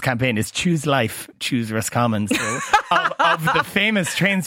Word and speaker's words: Roscommon: And actually, I was told before I came down here that it's not Roscommon, it's Roscommon. So I was saying it wrong, apparently. – Roscommon. – Instead of Roscommon, I was saying it as two words.